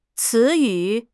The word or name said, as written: ci2 yu3